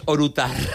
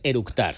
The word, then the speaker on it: eructar: y lo primero que hizo es orutar. – Con orutar es eructar.